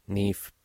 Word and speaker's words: Niamh: Pero de momento vamos a escuchar a nif Parsons que visitó el País Vasco la semana pasada. Bueno, la semana pasada, estos últimos días. Nif Parsons, Nif se escribe N-I-A-M-H, ¿eh? Nif Parsons.